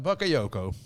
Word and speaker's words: Bakayoko: Uh, Bakayoko. Zeg ik dat goed? ja.